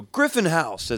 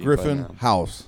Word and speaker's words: Griffin House: A lot of people go Griffin House as if it's a band or something, or a place or something. – You right. – Know, just put the accent on the wrong thing.